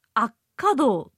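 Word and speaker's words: 安家洞: 方言空耳シリーズということでですね、これは私も全く知らなかった単語が出てきましたが、アッカド、安安、安全の安に家、そして道と書いてアッカドって読むんですね。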